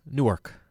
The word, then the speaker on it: Newark: All right, so we're going to get Many Saints of Newark review at some point in the show today. – Newark. – Huh? – Newark.